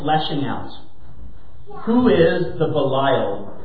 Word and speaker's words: Belial: It's more accurately pronounced Belial, but for the sake of something that I'll get to later in the sermon, I'm going to pronounce it Belial.